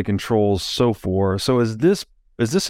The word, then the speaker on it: SOFR: Now, this is a bit more technical, but this transition from LIBOR to SOFR. I guess is how you pronounce this acronym, S-O-F-R. SOFR. Yeah. So I don't know a lot about this.